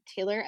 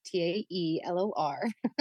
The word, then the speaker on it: Taelor: You can follow me on Taelor Effects on Instagram. Just make sure you spell my name right. It is really spelled like Taelor.